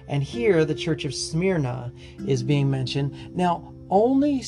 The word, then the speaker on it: Smyrna: Smyrna is how you say the name.